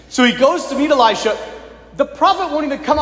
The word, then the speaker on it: Elisha: Many people will pronounce it Elisha. There's Elijah, right? The great prophet. And then the one who succeeds him, Elisha.